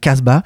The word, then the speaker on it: Casbah: Euh, alors c'est vous le prononcez avec en rajoutant un che à la fin. Peut-être que euh, dans d'autres régions du monde, ça se prononce comme ça. Non, c'est alors Rock the Casbah en fait, mais c'est la référence directe à un titre des Clash.